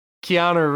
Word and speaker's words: Keanu: his name is pronounced Canoe Reeves. love you, Mr. Wiles. No, Keanu Reeves, they relied on advertising him being in the game so much that, you know, that crossover...